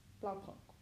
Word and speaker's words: Blaupunkt: I don't think you pronounced the T. It was Blaupunkt. – Blaupunkt.